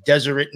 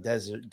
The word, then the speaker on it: Deseret: At first, I started noticing like LDS stuff, or like people's obituaries was on the Deseret News. Sorry, I've been saying it wrong the whole series. I've been saying desert.